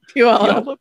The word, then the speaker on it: Puyallup: Puyallup. Puyallup? Yeah. It's one of it's one of our weird, well, it's not weird. I apologize. It's like comes from an indigenous word, so it's hard. Puyallup.